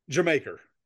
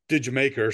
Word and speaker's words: Jamaica: So it is pronounced Jamaica. Because, you know, this not just Jamaica. He said you actually have to do it in an English accent, which sounds like did Jamaica, so Jamaica.